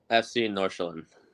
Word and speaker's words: FC Nordsjælland: FC Nordsjælland.